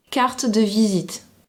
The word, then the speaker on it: carte de visite: Carte de visite. – Wait, what? – She says it differently. – Totally differently. – Carte de visite. – Now she pronounces the D.